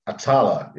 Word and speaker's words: Atalla: Atalla, yes, Lebanese.